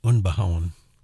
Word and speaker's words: Unbehaun: I'm in trouble, aren't I, with a name like Unbehaun. Actually, my name should be pronounced Unbehaun. German, and it means rough cut.